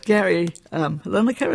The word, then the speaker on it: Gary Lineker: There was the, Gary Lineker, is that how his name is pronounced? – Yeah.